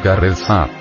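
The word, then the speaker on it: Karezza: La palabra careza, pronunciada carezza, viene del italiano y quiere decir una caricia.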